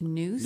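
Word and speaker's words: nous: I like just saying mind of the heart, but it's a Greek word that I was told called pronounced noose.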